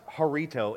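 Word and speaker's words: harito (χαριτόω): But it's pronounced harito, and it sounds like the little Spanish-Mexican little drinkaroonies, right?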